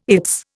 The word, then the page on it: its: Indian